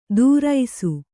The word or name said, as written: ದೂರೈಸು